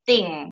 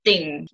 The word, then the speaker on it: Th'ng: How do you pronounce my last name is Ting. So Melissa Ting.